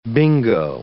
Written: bingo

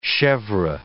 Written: chevre

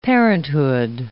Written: parenthood

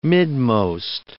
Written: midmost

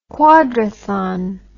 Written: quadrathon

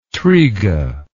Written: triga

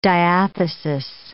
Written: diathesis

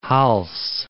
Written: Hals

